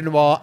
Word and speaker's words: Noix: N-O-I-X. – Okay. – Is that pronounced noir as well? – Oh, yeah. Right. – Well, anyone know? It is.